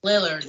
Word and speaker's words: Lillard: Lillard.